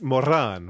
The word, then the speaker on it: Moren: It's pronounced Moran, right? – Because it rhymes with foreign.